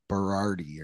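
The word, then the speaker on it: Boiardi: It's like, it's not Boyardee, it's like Berardi or something almost.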